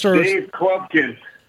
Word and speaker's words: Dave Klepkin: Mr. Dave Klepkin. – Klepkin, it rhymes with Blumpkin.